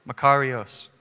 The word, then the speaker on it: makarios: How do you pronounce it? Say it again. Makarios. Makarios? Makarios. I butchered it still, but don't call me out. I can't roll my R's, bro.